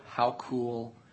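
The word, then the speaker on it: Haukohl: His name is Kurt Howcool, and it's not spelled like I just pronounced it, but I think that is the way you pronounce it. It's H-A-U-K-O-H-L.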